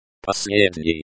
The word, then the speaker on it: последний: Последний. Последний means last, and can also be used to refer to something that is final. The stress is on the second syllable, posled, pronounced последний.